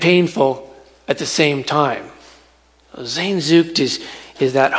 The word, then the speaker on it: Sehnsucht: Germans have a word pronounced Sehnsucht. Sehnsucht.